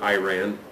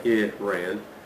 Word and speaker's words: Iran: My considered understanding of the name Iran, Americans pronounce it Iran, we do long I's in America. Most everybody else would do a shorter I, Iran, as a holdover from the Parthian Empire days.